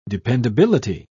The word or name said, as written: dependability